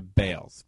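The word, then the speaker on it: Baals: The family now changed the pronunciation of their last name to Bales, B-A-L-E-S. which B-A-A-L.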